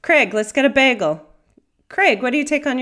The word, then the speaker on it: Craig: Craig and Craig. – Craig is what I should say. Craig, like an egg. – Craig to rhyme with Greg. No, Craig to rhyme with bagel.